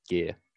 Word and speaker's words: Guia: and the Spanish side is G-U-I-A, which is pretty short and looks pretty easy, but I've heard so many variations of that. Guia, and just all sorts of different variations of people like trying to say it correctly. And I really have no problem if someone like just asks me, and it's actually quite simple. It's just gear.